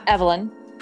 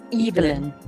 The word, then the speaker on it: Evelyn: Not Evelyn. It's Evelyn. – It makes sense. – That just sounds very on the nose. Like that's cringe almost. – Yeah, I don't like that at all. – Oh, it's also how the British pronounce it.